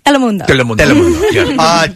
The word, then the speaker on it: Telemundo: Telemundo.